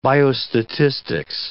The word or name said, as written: biostatistics